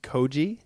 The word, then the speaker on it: koji: The one that caught my eye, I'd never heard about, and tell me if I'm pronouncing this correctly, is koji. – Believe so.